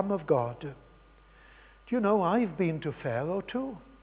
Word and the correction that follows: Faro: I've been to Faro in Portugal.